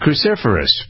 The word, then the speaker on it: cruciferous: I pronounced it right. It's cruciferous, but cruciferous.